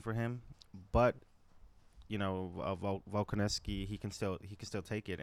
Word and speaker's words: Volkanovski: Volkanovski. Volkanovski.